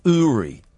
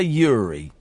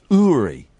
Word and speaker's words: Uri: Um, TV Psychic, and here's the thing his name is pronounced Uri. Uri. We all want to say Uri, but it's pronounced Uri.